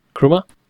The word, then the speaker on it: Chrooma: After that, Kruma GIF keyboard. How do you pronounce that? – Chroma. – Chroma. C-H-R-O-O-M-A. GIF keyboard. Then we have contextual app folder, C-A-F.